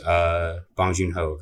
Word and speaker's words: Bong Joon-ho: So yeah, The Host, Bong Joon-ho.